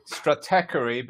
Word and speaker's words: Stratechery: One of your favorite sites, I know, is Stratechery. I don't know how to pronounce it. – Stratechery, but it's one of the most read sites, blogs in Silicon Valley.